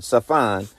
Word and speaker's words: Tsaphon: The north direction in Hebrew is Safan. It's the name Safan. It's spelled T-S-A-P-H-O-N, Safan,